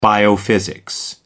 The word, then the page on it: biophysics: American English